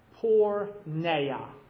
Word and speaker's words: porneia: The word that our Bibles translate as evil, this is how you pronounce it in the Greek. Listen carefully. Porneia. P-O-R-N-E-I-A.